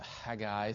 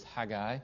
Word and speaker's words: Haggai: and he always called it Haggai. So if you can pronounce it that way, that's great. I cannot quite do that, so I will do what's called the southern equivalent of that, which is Haggai.